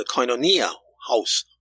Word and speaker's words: Koinonia: I thought I would share with you an article that I found in a website called the Koinonia House website. That's spelled K-O-I-N-O-N-I-A, Koinonia House website.